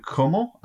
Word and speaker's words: Cuomo: It's looking like Andrew Cuomo. Am I saying his name right? – Yeah. – Cuomo.